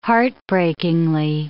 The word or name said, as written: heartbreakingly